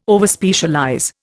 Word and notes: Overspecialize: Indian

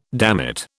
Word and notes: dammit: British English

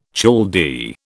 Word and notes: Chaldee: British English

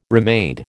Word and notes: remade: American English

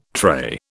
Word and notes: trait: British English

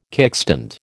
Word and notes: kickstand: American English

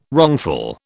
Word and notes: wrongful: British English

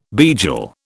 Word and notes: beigel: British English

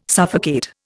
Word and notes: suffocate: Indian

